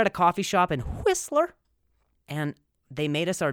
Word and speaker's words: Whistler: And we went up to a place called Whistler, okay? And I'm not trying to emphasize the H; it is spelled that way. So we went up to Whistler for Valentine's Day, and it was just lovely.